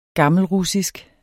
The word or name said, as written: gammelrussisk